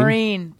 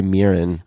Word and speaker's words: Mirren: In her book, she even says it's Marine, like M A R I N E.